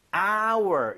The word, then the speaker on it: hour: 이게 our 묵음이 아니라 네. our 이렇게 발음이 되는 거예요. 발음 자체가 모음 사운드예요.